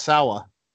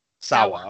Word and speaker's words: Sawa: Like we've mentioned a few of them already, but main character Anton Tobias, played by, how do you say his last name? Is it Devin Sawa? – I think it's Sawa. – Sawa.